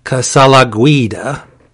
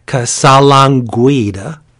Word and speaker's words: Casalanguida: This was written by Christopher Cantwell, with art by Luca Casalanguida. No, I didn't say that right. I'll try it again. Casalanguida. There we go.